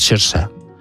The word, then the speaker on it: Saoirse: Saoirse Ronan, que como es gaélico es Saoirse. Es como se pronuncia.